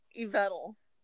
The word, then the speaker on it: Yveltal: Yveltal.